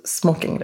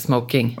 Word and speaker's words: smoking: Så gillade jag att du uttalade just smoking rätt. – Ja, du menar inte smoking? – Mm. – Nej, för det handlar inte om rökning.